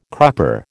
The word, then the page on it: crupper: American English